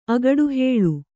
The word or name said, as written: ಅಗಡುಹೇಳು